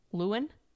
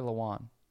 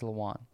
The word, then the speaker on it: Lewan: No, Taylor Lewan. – That's it. – It's Lewan. – Yeah. – To where people keep saying, or they always said Lewin. – It's spelled like that. It's L-E-W-I-N. – No, A-N. It's A-N.